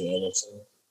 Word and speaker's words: Ellison: You find me on there, Alonzo Ellison. Also, and by the way, I totally butchered last time. I said Elizondo, man. Ellison, brother. Elizondo. No. Sorry.